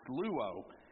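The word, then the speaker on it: luo (λύω): This word destroy in the Greek is luo, which is spelled L-Y-O, but it's pronounced luo.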